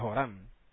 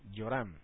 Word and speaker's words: Yoram: O sea, que había dos reyes con el mismo nombre, Yoram, en las versiones tradicionales se escribe con J, ¿verdad? Joram. En hebreo se escribe con Yod, Yoram.